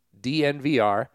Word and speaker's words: DNVR: You would think it would be called Denver and they'd just say it like a tech company, but it's not. It's just DNVR.